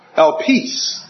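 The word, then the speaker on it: elpis (ἐλπίς): In the Greek, I believe it's pronounced elpis.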